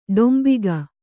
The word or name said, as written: ಡಾಂಬಿಕ